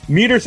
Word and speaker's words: Medeiros: I'm Nasty Neil, and I'm joined by director Garry Medeiros.